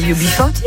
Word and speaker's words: UB40: UB40 ou je sais pas comment on prononce en anglais. – UB40. – Mais c'est... – Ah mais c'est ça, c'est UB40, c'est ça? – Ah oui.